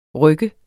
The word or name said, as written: ryk